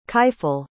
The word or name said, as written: kifle